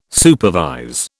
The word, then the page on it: supervise: British English